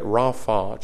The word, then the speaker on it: Rapha: It's pronounced like R-A-W-F-A-W, if that helps you later on. If you want to jot that beside it, Rapha, Jehovah Rapha, the God who heals.